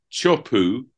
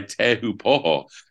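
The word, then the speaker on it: Teahupoo: Well, the winner, whose name I'm struggling to pronounce, because Chupu, which we thought was the standard pronunciation, I noticed from a search engine, has now been replaced by Tehu Poho.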